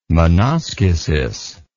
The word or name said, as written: menoschesis